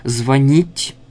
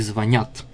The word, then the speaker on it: звонит: «Звонить», «звонит», «звоним», «звонишь» и «звонят». Вот все-таки правильное произношение. «Позвонит», «созвонимся», «перезвонят», везде ударение на конце. – Угу. Не звонят.